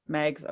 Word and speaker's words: Meigs: well, they they sent Montgomery like Montgomery Meigs, Megs, how do you say his name? Because Eggs. – Megs, okay, good.